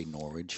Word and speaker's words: Norwich: I'm going to assume that's pronounced Norwich. Maybe it's Norwich. – No, I'd say Norwich.